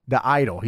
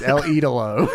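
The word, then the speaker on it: El Ídolo: He said Andrade the Idol. He said El Idolo. It's El Idolo.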